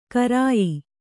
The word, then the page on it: ಕರಾಯಿ: karāyi; Kannada